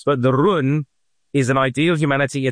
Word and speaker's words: jen: The goal is, the spelling is J-E-N, but it's pronounced run, is the superior man.